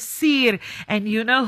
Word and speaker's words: Siir: Well, it is spelled S-I-I-R and pronounced Seer.